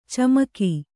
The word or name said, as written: ಚಮಕ